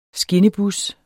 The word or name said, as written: skinnebus